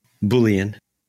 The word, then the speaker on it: Boolean: I I've, is it Boolean? – Boolean. – Oh, Boolean. My bad.